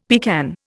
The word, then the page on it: pecan: Indian